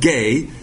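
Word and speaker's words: gay (γῆ): So 2 Peter 3:7-9 says, by his word, logos, the present heavens, oranos, and earth, that word is pronounced gay, but it's spelled G-E, are being reserved for fire, kept for the day of judgment, krisis, and destruction of ungodly men.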